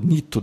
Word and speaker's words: Neato: E aquele Nito, não sei como é que fala isso, é, N-E-A-T-O, Neato, Nito deve ser, né?